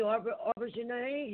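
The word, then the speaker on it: Auberjonois: Rene Auberjonet. He just passed away. – And you said his name right.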